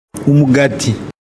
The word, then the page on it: Umugati: Kinyarwanda